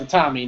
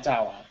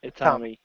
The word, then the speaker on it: Itami: First of all, it's Itami, not Itawa. Itami.